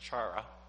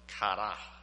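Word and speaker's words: kara (χαρά): So joy comes from a Greek word, and it's not pronounced chara. I don't like to think about chara. It plays for the Boston Bruins. Okay. That's not how it's pronounced. It's actually pronounced kara.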